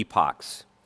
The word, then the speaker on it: epoch: Now, I just want to say quickly, the word E-P-O-C-H can be pronounced either epoch or epic. In fact, in our country, it's most likely pronounced epic. But so I don't confuse it with the word spelled E-P-I-C, I'm going to use the United Kingdom pronunciation of epoch for the rest of the sermon.